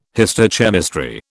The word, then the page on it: histochemistry: British English